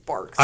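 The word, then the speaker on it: Barq's: So everybody write in and tell him it's Barks. – I think it was probably just like people would say Barks and I would just not hear the K pronunciation and think it's like bars.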